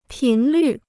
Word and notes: 频率: pín lǜ

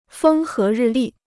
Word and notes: 风和日丽: fēng hé rì lì

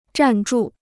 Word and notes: 站住: zhàn zhù

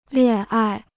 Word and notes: 恋爱: liàn ài

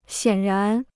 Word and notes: 显然: xiǎn rán

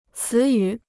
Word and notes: 词语: cí yǔ

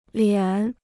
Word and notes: 脸: liǎn

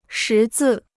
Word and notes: 识字: shí zì

